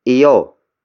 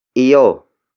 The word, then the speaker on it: iyo: Let's continue our lesson with the next locative pronoun, which is iyo. Iyo. Iyo. Iyo is spelled as I-Y-O.